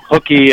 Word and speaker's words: Hukee: Yes, there's really no good way to say it in English, but Hukee, as in Hukee from school, at least people can remember that, right? – Gotcha.